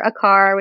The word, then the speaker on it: Aquart: Aquart which is spelled a-q-u-a-r-t like a quart of milk um, you could find me on facebook instagram youtube my Toller Puppy has her own stuff going on.